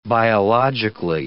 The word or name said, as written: biologically